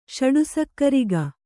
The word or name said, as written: ಷಡುಸಕ್ಕರಿಗ